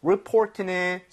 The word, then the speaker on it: report: 그리고 report는 사실 리포트, 레포트, okay? So 예. 신고, 보고서가 되겠습니다. Report, 이렇게 발음할 때 레가 아니라 르, report.